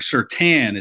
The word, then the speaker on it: Surtain: And it's Patrick Surtain is how the last name is said. I usually have been saying it Surtain. – But yeah, well, you're right. If you look up the pronunciation, yeah. It says Surtain.